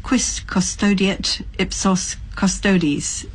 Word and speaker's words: quis custodiet ipsos custodes: Just on the question of Nkandla, I'm looking at the 19th of March this year, quis custodiet ipsos custodes.